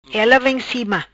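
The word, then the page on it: Ela vem cima: Patua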